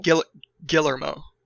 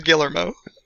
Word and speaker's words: Guillermo: Guillermo. – Guillermo. Guillermo. – Guillermo. – El Camino del Taco. – Remember the Guillermo. – The El Camino Trail. Oh It's Guillermo. It's Guillermo del Toro.